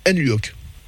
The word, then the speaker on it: Nluok: morceau intitulé Nluok, je ne sais pas si je le prononce bien. – C'est tout à fait comme ça qu'on prononce.